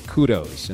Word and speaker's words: kudos: let's give kadoos to so-and-so. He meant to say kudos, and I'll never forget Dick Vermeil pronouncing kudos, kadoos.